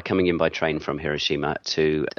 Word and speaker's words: Hiroshima: So, like, I believe the correct way to say Hiroshima is that way rather than Hiroshima, which is often how we would have said it in the West.